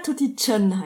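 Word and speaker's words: Tutty Tran: Immer wenn du von Vietnam erzählst, muss ich an Tutti Tran denken. Kennst du den? – Ja, Tutti Chan heißt der übrigens. – Oh, Entschuldigung. Wie heißt der? Tutti Chan? – Ja. Also das TR spricht man Ch aus.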